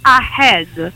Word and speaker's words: ahead: Ahead of, per cui avanti di, mm-hmm, e me la pronunciano regolarmente ahead. Regolarmente. – Che sembra che uno stia tossendo, non lo so, che abbia qualcosa. – Aggiungono l'H all'inizio, che non serve. Per cui dico, no ragazzi, staccate la A dal resto della parola, a head.